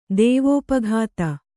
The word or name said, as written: ದೇವೋಪಘಾತ